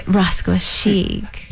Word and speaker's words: Rascuachic: You have to say it, Rascuachic. – You have to roll the R's.